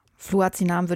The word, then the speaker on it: Fluazinam: Fluazinam würde ich das auch aussprechen, ja.